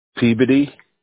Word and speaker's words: Peabody: Peabody. – Spell it. – P-E-A-B-O-D-Y.